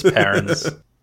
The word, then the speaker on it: Paruns: I say it Perrins as well. – Oh, this is just me being awful. Okay. – All right, you're outvoted. It's Perrins.